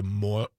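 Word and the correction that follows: Māori: tribe? – Maori tribe. – Thank you for correcting me.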